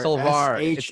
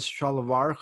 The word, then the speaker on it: shalwar: We pronounce it as shalwar kameez. He pronounces it as salvar kameez.